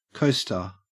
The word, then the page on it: coaster: Australian English